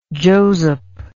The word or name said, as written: Josep